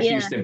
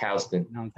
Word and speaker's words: Houston: It looks like Houston, but it's pronounced Houston.